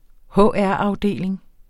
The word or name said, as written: HR-afdeling